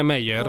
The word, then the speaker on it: Meyer: Ho detto bene Meyer? – Ho detto benissimo, sì.